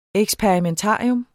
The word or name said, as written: eksperimentarium